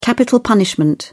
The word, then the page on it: capital punishment: British English